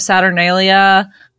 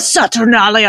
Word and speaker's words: Saturnalia: Like when you're saying the Saturn land or whatever, I was thinking of Saturnalia. I'm saying that like a total idiot. I know. – No, it's you said it right. – Saturnalia.